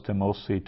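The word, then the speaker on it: timosi (τιμῶσι): So the timosi there, that's also subjunctive, so it's got a long O, an omega instead of timusi would be they honor, timosi they might honor.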